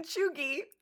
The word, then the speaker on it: Chugi: And then by not clarifying in the poll that the G was a hard G in Chugi, or that G, I thought that it was just understood that G would be G phonetically. G-E-E would be, hey, oh, G.